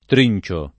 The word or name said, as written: trincio